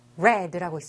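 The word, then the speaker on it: read: 자, 근데 read는 스펠링은 같아도 읽는 방법을 달라요. red라고 있습니다. 마치 빨간색, red, 빨간색이란 단어와 발음이 같아요.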